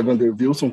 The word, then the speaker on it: Wander Wilson: Oi, gente, salve, sou o Vander, enfim, meu nome tem uma coisa engraçada, mas o nome na verdade é Vander Wilson, que deve ter os dois, as duas pronúncias. do W no mesmo nome, mas enfim, também pode ser Vander Wilson, tá tudo certo.